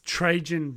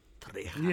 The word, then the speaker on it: Trajan: Unless it's a soft J and it's Trajan. – Trajan.